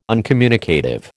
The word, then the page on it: uncommunicative: American English